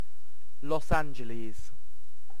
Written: Los Angeles

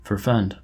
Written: forfend

